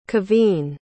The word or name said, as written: kevine